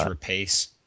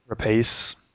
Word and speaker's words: Rapace: I'm happy Noomi Rapace, whatever, however you pronounce his name, is doing more work. But I thought it was Rapace. – Rapace.